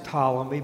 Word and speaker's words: Ptolemy: And it's going to be the Ptolemies, pronounced Ptolemy, but it starts with the letter P.